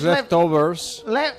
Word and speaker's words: Leftovers: La de El Fargo, Lovers. – Sí, que lo he dicho mal yo. – Ah, sí. Ah, es mal. No es, es no, que no, veo bien. – Ah. – es, Leftovers.